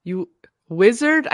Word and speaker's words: Uizard: It's called you Wizard, I think, but it's spelled U I Z A R D dot I O. You Wizard.